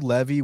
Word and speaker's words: Levi: Use promo code Levy, which is my last name. Not like Natan Levy. It's spelled like Levi Jeans, L-E-V-I.